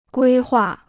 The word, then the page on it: 规划: guī huà